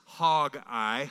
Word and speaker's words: Haggai: It's pronounced Haggai, Haggai.